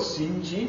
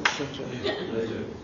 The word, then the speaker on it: St. John: How do you spell Sinji? – Oh, well, it's St. John, but I believe they pronounce it Sinji.